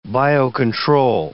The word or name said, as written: biocontrol